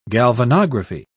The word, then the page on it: galvanography: British English